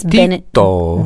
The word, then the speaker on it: Benedetto: I, of course, had to call up my mom and say, Mom, how do you say Benedict in Italian? Because they don't call him Benedict. I mean, it's Benedetto.